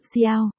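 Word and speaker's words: Xiao (小): Tiểu Xiao, với hiểu Xiao, đều đọc là Xiao, là từ đồng âm.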